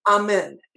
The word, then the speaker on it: Amen: How do you spell that name? – Amen. A M E N, the same way the Baptists.